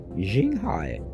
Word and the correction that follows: Xinghai: Xinghai, Xinghai, X I N G H A I.